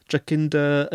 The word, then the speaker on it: Jacinda: Is it Jacinda? – Jacinda.